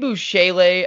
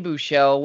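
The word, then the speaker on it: Buechele: Shane Bouchele, as you like to call him, or Shane Bouchel, whatever you prefer. It's definitely Bouchel, but if you want to say it like our concussed friend, Hugh Bouchele.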